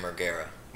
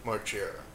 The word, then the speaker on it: Margera: How do you pronounce, it's Bam Margera or Bam Margera? – Margera. – Margera, yeah. Okay.